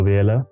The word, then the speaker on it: Biyela: As you so well pronounced, my name is Sibusiso Biyela.